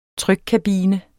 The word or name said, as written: trykkabine